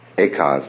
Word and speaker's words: Eka: Hueke in Chinese. Eka is Japanese pronunciation, like Zen is the Japanese pronunciation of the Chinese Chan.